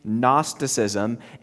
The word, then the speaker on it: Gnosticism: Silent G at the beginning, Gnosticism, and it was prevalent in the, in the time of the early church.